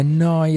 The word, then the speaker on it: Neu: Questa, come detto, è Noi Radio, Neu Radio, si scrive Neu, si pronuncia Noi e vuol dire nuova, nuova emittente urbana.